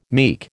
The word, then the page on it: meek: American English